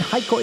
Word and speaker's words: Heiko: Heiko is my name. It is spelled H E I K O. It's a German name.